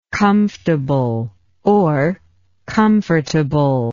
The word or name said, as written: comfortability